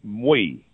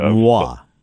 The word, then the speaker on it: Moi: It's Muay. It's think of the term Muay, like my in French. That's the way it's spelled. – But not Muay. – Not Muay. Yeah. – It's spelled M-O-I. – Yes, M-O-I. – But it's pronounced like the Jewish Muay.